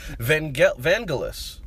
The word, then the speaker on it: Vangelis: I'm going to get his name wrong again. And I think Paul just shared with me a link. – He had two different ways to say it. – Pronunciation guide, and I'm still gonna get it wrong, but I'm gonna just call him Vangelis. – I think it's Evangeli. – I think it's a hard G now. Somebody told me. – Vangeli. – No, honey. Vangelis. – Vangelis. But it can't be S.